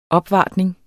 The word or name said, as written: opvartning